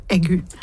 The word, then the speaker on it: aigu: Aigu? Hvis jeg så, det sånn du uttales? – Nei. – Nei. – Egu. – Egu? Ok.